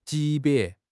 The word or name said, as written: 级别